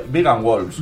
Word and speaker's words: Vegan Wolves: Yo voy a ver si lo digo bien. Le voy a mirar la camiseta a uno de ellos. Vegan Wolves o Wolves. No sé si vamos a entender los micros y estas cosas. A ver, no sé si lo he dicho bien. Vegan Wolves. – Vegan Wolves.